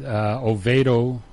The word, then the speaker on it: Oviedo: So tonight, today's game, Oviedo, is that how it's pronounced? – I believe so.